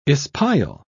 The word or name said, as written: espial